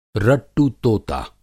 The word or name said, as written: रट्टु तोता